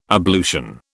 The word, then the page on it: ablution: British English